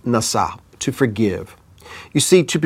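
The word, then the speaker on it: Nasah (נשה): There is yet another family word that I want to introduce to you, and it is pronounced Nasah, spelled Nun Sin He, and it means to forget.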